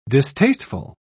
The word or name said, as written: distasteful